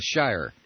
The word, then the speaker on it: Shirer: a book by William Shearer, or Shirer, I don't know the pronunciation. It's called The Nightmare Years, The Rise and Fall of the Third Reich. – Oh, yeah, Shirer.